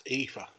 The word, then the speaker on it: Aoife: All right, number six, we have, you got to say her name correctly, but it's Aofi Valkyrie. How you say her name? – That's Aoife, isn't it? A-I-F-E.